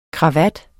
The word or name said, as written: KRAVATTERNES